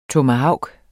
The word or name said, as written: TOMAHAVKEN